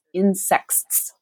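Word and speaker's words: Insexts: So the book she's talking about here is Insects, which is hard to say on a podcast, but is spelled I-N and then S-E-X with a little T-S at the end.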